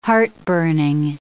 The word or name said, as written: heartburning